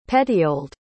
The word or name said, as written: petioled